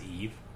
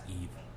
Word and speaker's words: Yves: Did you know that that name is Eve? Y-V-E-S? – Is it? – It's pronounced Eve.